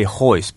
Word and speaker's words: Royce: There are people who think the R should be pronounced, that it's more just a Gracie thing about not pronouncing the R. – Right, okay. But we say hoist because that's how he says it, right? That's how the Brazilians say it, right? – That's how he says it.